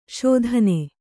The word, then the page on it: ಶೋಧನಿ: Kannada